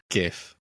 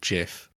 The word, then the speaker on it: GIF: Can we just be clear that it is GIF and not JIF? – Yeah, it's a hard G. It's a GIF.